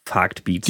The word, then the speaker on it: Vagtbeats: unter dem Künstlerprofil Farkt Beats. – Genau. Das habe ich jetzt auch hoffentlich richtig ausgesprochen. – Ja, richtig.